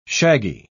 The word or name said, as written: shaggy